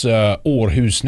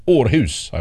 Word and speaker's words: Aarhus: But it's pronounced Aarhus, I believe.